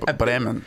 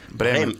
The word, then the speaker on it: Bremen: Is it how you say it though? Bremen. – Bremen.